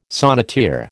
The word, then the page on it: sonneteer: American English